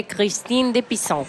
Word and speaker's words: Christine de Pizan: La Cité des Dames de Christine de Pizan. – La Cité des Dames de Christine de Pizan.